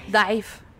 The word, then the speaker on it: Daif: Who is not here by some miscommunication, and we really, really, really miss her, is Maria Daif, or Daif. How do you say it? Daif? – Daif. – Daif. Hard to pronounce for us.